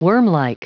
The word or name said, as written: wormlike